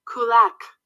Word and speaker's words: Kulak: Kulak.